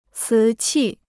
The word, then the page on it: 瓷器: cí qì